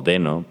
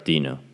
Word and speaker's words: Deno: Porque como te mencionaba, la persona que escribió eh, Dino, que por cierto hay una, un debate entre si se pronuncia Dino o Deno, pero al parecer se está quedando con pronunciar Dino por su logo de que es un dinosaurio.